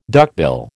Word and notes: duckbill: American English

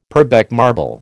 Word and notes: Purbeck marble: American English

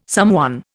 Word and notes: someone: Indian

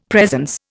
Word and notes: presence: Indian

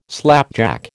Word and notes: slapjack: American English

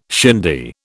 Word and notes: shindy: British English

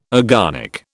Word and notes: agonic: British English

